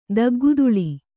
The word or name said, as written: ದಗ್ಗುದುಳಿ